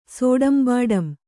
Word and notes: ಸೋಡಂಬಾಡಂ: sōḍambāḍam

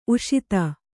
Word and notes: ಉಷತಿ: uṣati

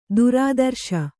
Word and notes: ಥರಥರಿಸು: thara tharisu

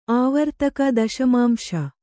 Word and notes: ಆವರ್ತಕ ದಶಮಾಂಶ: Kannada

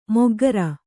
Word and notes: ಮೊಗ್ಗರ: moggara

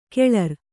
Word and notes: ಕೆಳರ್: keḷar

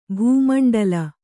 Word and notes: ಭಾಮಂಡಲ: kannada

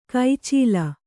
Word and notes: ಕೈಚೀಲ: kai cīla

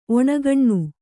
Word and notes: ಒಣಗಣ್ಣು: oṇgaṇṇu

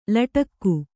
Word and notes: ಲಟಿಕಿ: kannada

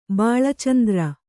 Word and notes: ಬಾಳಚಂದ್ರ: bāḷa candr

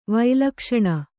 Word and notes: ವೈಲಕ್ಷಣ: Kannada

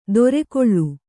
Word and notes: ದೊರೆಕೊಳ್ಳು: dorekoḷḷu

